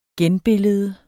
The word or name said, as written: genbillede